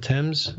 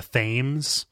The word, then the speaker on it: Thames: London has the one, was it the Thames running down it or whatever? – I think you mean the Thames,